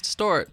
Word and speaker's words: DI$TXRT: Yeah, well, it's DI$TXRT. I know it's confusing. Yeah, it is. But it's pronounced Destroy.